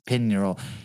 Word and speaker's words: Pignerol: Uh, Fouquet, whatever, spent 15 years in the fortress of Pignerol, P- Pignerol, it's pig and then neural. I imagine that G is silent. Pin neural.